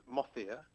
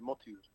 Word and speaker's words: Mohitur: The Anglicized of it, I guess, as I've grown up with it, it's Mothia. – Mothia. – What I've learned, though, is that the Arabic is, the pronunciation should be Mothur.